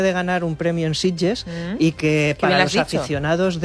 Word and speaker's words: Sitges: No ha dicho Sitges, ha dicho Sitges. – Lo ha dicho muy bien. – Lo ha dicho con buena pronunciación catalana.